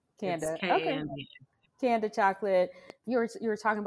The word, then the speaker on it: Kanda: Wondering though, what you said before founding Kanda Chocolate. Am I saying it correctly? – Mm-hmm, but I welcome everyone that I'm not a stickler. So it's Kanda Chocolates, but I Kanda.